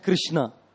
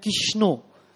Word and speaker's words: Krishna: And then you will find Bengali devotees chant Kishno. All kinds of the pronunciations will hear, you know, Krishna, Krishna, Kishno, Kishno, some chant Hare Kisan.